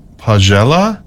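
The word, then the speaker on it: Paghjella: So, and it's pronounced Pagella is what I'm finding as a way of pronouncing. And Pagella is a male singing tradition.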